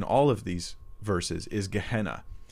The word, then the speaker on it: Gehenna: We pronounce it, you know, Gehenna.